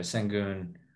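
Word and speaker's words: Şengün: Sengun, we should start saying that. That's how it's said.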